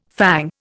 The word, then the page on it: fang: Indian